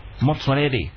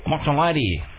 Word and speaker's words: Motsoaledi: You got to say the swan away. Try again. Arun who? – Arun Motsoaledi. – Motsoaledi.